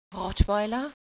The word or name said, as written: rottweil